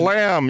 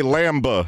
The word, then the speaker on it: lamb: Lamb has a B on the end. Yeah, but lamb. You don't say lamba. – The B is silent.